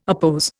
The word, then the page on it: oppose: Indian